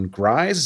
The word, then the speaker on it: Gries: His brother, Harvey, played by John Grise. Is that how you pronounce it? G R I E S? Yeah, not Grise, sure. Grease? – or Grise?